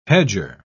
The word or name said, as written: hedger